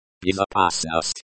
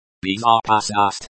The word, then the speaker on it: безопасность: Безопасность. – Безопасность, safety. The stress is on the third syllable.